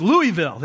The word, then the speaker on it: Louisville: Did I said it right? I think I said enough Southern there. Louisville, right? Where I'm from is Louisville. There you go.